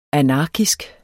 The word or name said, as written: anarkisk